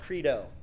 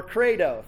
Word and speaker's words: credo: Sometimes it's pronounced credo. C-R-E-D-O, or credo. Think Plato, but credo.